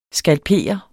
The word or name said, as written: skalp